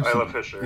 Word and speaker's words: Isla Fisher: And then I move on to the villains, which we have a couple of really great performances with Matthew Good and Ilsa, how do you say, Ilsa Fisher? Isla Fisher? – Yeah. – Isla Fisher? – Isla Fisher.